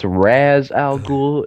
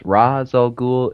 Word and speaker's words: Ra's al Ghul: It's Ras Al Ghoul. It's Ras Al Ghoul.